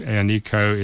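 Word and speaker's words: Aglianico: So, as I say, it's pronounced Aionico. It's spelled Aglanico, A-G-L-A-N-I-C-O.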